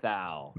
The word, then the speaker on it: Mathieu: I don't think it's Matthew. It's like spelled weird. – It's Mathau.